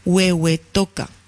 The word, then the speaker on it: Huehuetoca: al comedor para migrantes de Huehuetoca. ¿Lo he dicho bien? – Sí, sí, sí.